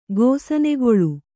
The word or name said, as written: ಗೋಸನೆಗಳೆ